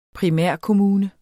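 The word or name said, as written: primærkommune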